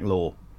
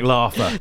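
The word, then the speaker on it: Laugher: I mean, the way it's spelled, it is Laffer, but yeah. – It is Law.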